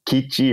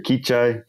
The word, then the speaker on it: Kichai: So the Northern Kadoan languages are Pawnee, they're Arikara, they're Kichi or Kichai. I've heard it pronounced both ways.